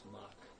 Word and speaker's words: SMOK: I think it's pronounced smock. – Smock.